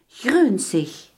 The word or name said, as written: groenig